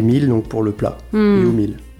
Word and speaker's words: U Meal: Humil, alors j'ai mal prononcé, voilà, ça commençait pas bien. – Oui, vous auriez dû me le dire. – Humil, c'est you en anglais, donc toi.